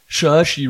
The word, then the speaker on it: Saoirse: Und Saoirse Ronan, die man so ausspricht, das habe ich in einem Interview mit der, hat sie das selber mal ausgesprochen so, was ja nicht immer einfach ist bei so irischen Namen, die kennt man ja aus Little Women zum Beispiel, Brooklyn hat sie mitgespielt, Lady Bird, also auch lauter coole Filme eigentlich.